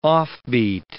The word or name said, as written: offbeat